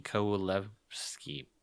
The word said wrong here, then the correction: Kowalewski: No. No, there's an extra syllable. – Oh, there's one more? Okay.